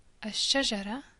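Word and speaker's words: a shajara (الشجرة): So instead of saying L samak as we've done up until now, we say a samak, a nur, a shajara, a tayyar, a dabit.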